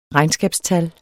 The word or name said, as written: regnskabstal